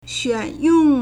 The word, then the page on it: 选用: 選用; xuǎnyòng